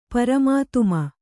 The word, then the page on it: ಪರಮಾತುಮ: Kannada; paramātuma